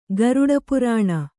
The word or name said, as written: ಗಾರುಡಪುರಾಣ